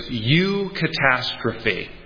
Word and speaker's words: eucatastrophe: And the term is U-catastrophe. So it's spelled catastrophe with an E-U at the beginning of it. U-catastrophe. So the prefix E-U means good.